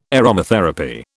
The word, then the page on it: aromatherapy: British English